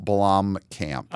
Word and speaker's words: Blomkamp: So it is interesting because this is directed by Neil, what's his last, how do you pronounce his last name? – Neil Blomkamp.